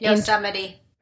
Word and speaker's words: Yosemite: So in 2019, it looks like the couple traveled to North Carolina, Texas, Colorado, Santa Monica, Yosemite National Park, and Las Vegas. And then Yosemite.